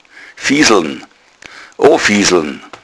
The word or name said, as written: fieseln